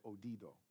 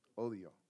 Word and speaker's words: odio: I said it right this time, right? Last time I said odido. Odio.